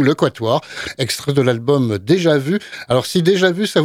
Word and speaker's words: Déjà Vu: elle s'écrit d'ailleurs avec les accents comme en français, Déjà Vu, ça se prononce comment à la française.